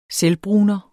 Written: selvbruner

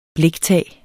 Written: bliktag

